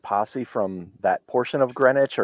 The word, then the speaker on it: Greenwich: And then the other, so in the wintertime, it's called Greenwich Mean Time, correct? I got that right. – Correct. Yes. – I said it right. I'm glad I've been saying it right this whole time.